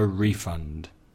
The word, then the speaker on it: refund: The stress changes. Refund, the verb, stress on the second syllable. Give someone a refund, the stress is on the first syllable.